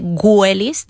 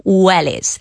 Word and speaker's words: wellies: Recordad que esa W se pronuncia como una U, nada de wellies, decimos wellies.